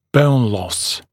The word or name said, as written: bone loss